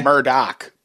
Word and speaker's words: Murdaugh: It's actually Murdaugh, but that's not how I was pronouncing it when I researched it.